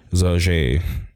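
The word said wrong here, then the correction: José Aldo: I fucking said his name wrong. It's getting late. Jose Aldo number 4, Frankie Edgar 5, and Zabit, Chan Sung Jung, Jeremy Siemens, and Josh Emmett.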